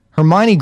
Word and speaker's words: Hermione: Hermione Granger. – I've never seen one of those movies, but I know her name's Hermione.